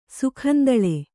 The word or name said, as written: ಸುಖಂದಳೆ